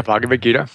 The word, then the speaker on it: Bhagavad Gita: By the way, Bhagavad Gita.